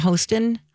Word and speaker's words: Hostin: Hostin? – Yeah.